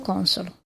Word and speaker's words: Consolo: Vincenzo Consolo. – È una voce del verbo consolare, ma con l'accento su un'altra vocale.